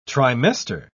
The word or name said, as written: trimester